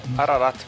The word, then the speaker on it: Ararat: Ararat pode.